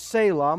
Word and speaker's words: Selah: It's a Hebrew word pronounced Selah.